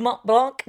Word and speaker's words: Mont Blanc: I mean, the fans in your Ultra Tour du Mont Blanc, did I say it right? – Perfect.